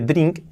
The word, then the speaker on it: drink: Cuidado que se escribe drink y la pronunciación es pues un poco complicada, porque tenemos la R, y luego esa I de de drink, que no es una I, es una E prácticamente. Por lo tanto sería drink drank.